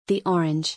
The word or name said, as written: The orange